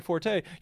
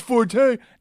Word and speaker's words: forte: excuse me, pronunciation is fort. Yeah. But you can lay the trap so that people go, oh, it's forte. Now, here's the thing. Most dictionaries these days actually say that both are acceptable because of popular usage.